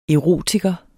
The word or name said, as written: erotiker